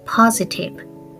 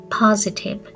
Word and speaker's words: positive: で、発音は positive, positive ね。えっ、ー、と、 si のところが、ここが z の、えー、ね、音、 z の発音になりますね。